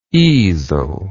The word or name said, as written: easeled